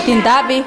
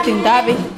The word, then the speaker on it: Tindapi: How do you say that? – Tindapi. Tindapi.